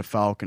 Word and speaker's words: Fouke: Spelled F-O-U-K-E, but you pronounce it Fouke.